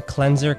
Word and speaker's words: cleanser: Right, the original form of the word is clean, but in this case we change the pronunciation but keep the spelling and we say cleanser.